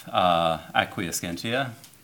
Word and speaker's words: acquiescentia: I want to talk about this notion in Spinoza of acquiescentia.